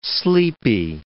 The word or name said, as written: sleepy